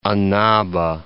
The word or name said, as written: Annaba